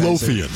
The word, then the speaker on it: Midlothian: Okay, speaking of here, Midlothian, Virginia? – Yeah. – Is that how you say it? – Yeah, that's exactly Midlothian, right.